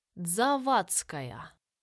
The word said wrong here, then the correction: Завадская: «Нет», — поправила Саша, взглянув на паспорт в руках полицейского. «Завадская. Вы читаете по-итальянски, а правильно произносится по-другому».